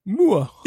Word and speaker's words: Muir: Så der er ham her biolog, en, en sådan evolutionsbiolog ved navn Dr. William, og jeg tror, det udtales Muir. – Muir? – M-U-I-R. Det, det lyder lidt fransk, gør det ikke? M-U-I-R? Ja, Mur. Ja, Mur. Ja, vi kalder ham Mur.